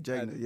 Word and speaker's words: Jegna: I feel like taking people under your wing and being not a mentor but a jegger, right? – Jegna, yes sir. – How you say it? – Jegna. – Jegna, yeah, is the correct term